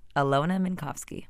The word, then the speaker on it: Alyona Minkovski: Alyona Minkovski.